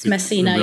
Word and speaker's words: Messina: Messina? Is that how you say it? – It's Messina, yes.